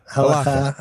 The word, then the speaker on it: Halakha: Halakha.